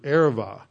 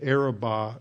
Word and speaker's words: Arava: And the word for plains is the word Arava. And it's pronounced, it's a soft B, so it's pronounced like a V. But it is usually pronounced today the Araba.